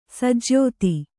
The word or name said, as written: ಸಜ್ಯೋತಿ